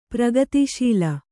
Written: ಪ್ರಗತಿಶೀಲ

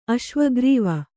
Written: ಅಶ್ವಗ್ರೀವ

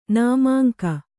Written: ನಾಮಾಂಕ

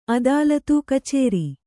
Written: ಅದಾಲತು

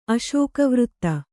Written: ಅಶೋಕವೃತ್ತ

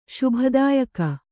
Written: ಶುಭದಾಯಕಿ

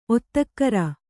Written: ಒತ್ತಕ್ಕರ

